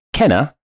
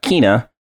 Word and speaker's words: Kena: So we'll be talking about Life is Strange and the recently released Kena Bridge of Spirits, and it's pronounced Kenna and not Kina and not Kana. So uh, there you go. But in the game, for some reason, they pronounce it Kana.